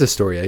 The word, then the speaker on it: Astoria: Astoria? – I think it's Astoria, yeah.